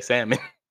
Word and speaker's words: salmon: If I'm around my family, I say salmon. – So whose bright idea was it to not pronounce the L? – America.